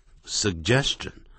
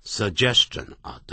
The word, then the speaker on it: suggestion: 所以第一个 G 给前面，第二个 G 给后头， suggestion, suggestion。念的时候这边顿一下， suggestion。不要念成 suggestion 啊，特别记住哦。